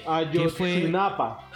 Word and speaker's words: Ayotzinapa: Pero empezaron a ganarse todo mi respeto cuando sucedió lo de la masacre de Azotzihualpa, perdón si no lo pronuncio bien. – Ayotzinapa. – ¿Cómo? Perdón. – Ayotzinapa. – Eso, de Ayotzinapa, perdón.